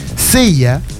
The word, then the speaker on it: Ceya: Sella, se escribe C-E-Y-A, claro.